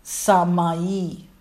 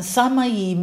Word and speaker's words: semaino: Group number one, the word sign is called samai, samai, and the spelling is S-E-M-A-I-N-O, but it's pronounced S-A-Y dash M-A-H dash Y-E-E. And samayi means to indicate or to signify.